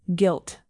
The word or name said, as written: guilt